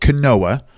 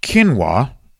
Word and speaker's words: quinoa: It's spelled quinoa. It's pronounced quinoa.